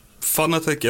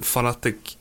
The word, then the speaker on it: Fanatec: Fanatec eller Fanatec, det spelar inte ingen roll, säger vår vd själv, utan det är lite vad man vill.